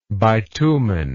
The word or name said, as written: bituminoid